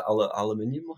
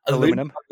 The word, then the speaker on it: aluminium: Aluminium, or what is it? Aluminium. How do you say it over there? – Aluminum. – Aluminum.